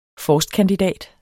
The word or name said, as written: forstkandidat